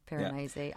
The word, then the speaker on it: Piranesi: We read Piranesi by Susanna Clarke. Is that how it's pronounced? – Yeah, that's how I read it. – Okay, good. Piranesi. Yeah.